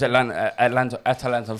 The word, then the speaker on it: Atalanta: Valencia, Atlanta. I've gone 2-1 Valencia. But that still puts Atalanta through. – Thank you for pronouncing them correctly eventually.